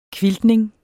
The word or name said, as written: quiltning